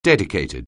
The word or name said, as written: dedicated